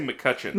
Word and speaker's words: McCutcheon: and picks up a bottle of McCoochin. Did I say that right? – I say McCutcheon. – McCutcheon. But... That's... Mm-hmm. McCoochin sounds weird. McCutcheon. Okay.